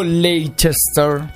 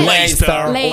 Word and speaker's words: Leicester: Il C in mezzo è praticamente inutile, useless. Non si sa perché, ma è proprio inutile. Quindi la pronuncia è tutti insieme Leicester.